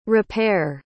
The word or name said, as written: rapere